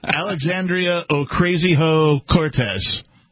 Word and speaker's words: Alexandria Ocasio-Cortez: Alexandria Ocasio-Cortez, I believe is the correct pronunciation.